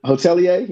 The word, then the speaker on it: hotelier: Hotelier.